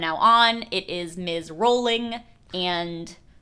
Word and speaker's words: Rowling: It's not Rowling, it's Rowling. – Yeah, as in bowling.